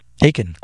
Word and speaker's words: Achan: Is it Achan? – Yeah, that's right. Achan. – Achan, like belly Achan.